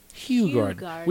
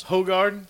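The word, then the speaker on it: Hoegaarden: Hugh Garden. We pronounced it wrong for years. – Hogarden? – Yeah.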